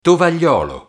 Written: tovagliolo

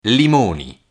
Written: limoni

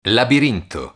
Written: labirinto

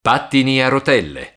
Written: pattini a rotelle

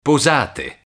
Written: posate

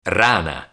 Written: rana

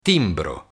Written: timbro

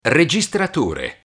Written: registratore

